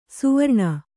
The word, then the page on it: ಸುವರ್ಣ: suvarṇa